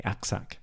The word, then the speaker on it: aksak: In fact, they borrow it from Turkey, and it's the Turkish word aksak, A-K-S-A-K, aksak.